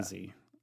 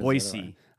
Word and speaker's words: Boise: They get very, very particular about how you pronounce Boise. It's like B-O-Y hyphen C. It's like you with Nevada, Nevada. – Boise.